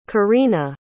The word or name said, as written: korina